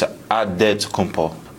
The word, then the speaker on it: Adetokounmpo: In the Nigerian way, you got to say it with a D. So it's Adetokounmpo. But in the Greek way, you say with the NT, like Antetokounmpo.